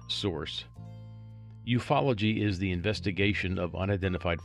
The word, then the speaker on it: ufology: You know, the other day when I was doing one of our episodes, I was confused by a word that I saw, ufology, U-F-O-L-O-G-Y, and it is pronounced ufology.